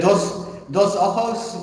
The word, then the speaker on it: Dos Ojos: You connected the, and I apologise if I butcher this pronunciation, the Sakaktun and the Dos Ojos. – It's the Dos Ojos, which means two eyes in Spanish.